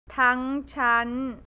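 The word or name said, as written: ทั้งชั้น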